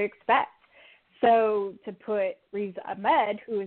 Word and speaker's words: Ahmed: Ahmed? – Riz Ahmed.